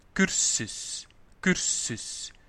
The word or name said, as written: de cursus